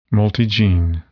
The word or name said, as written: multigene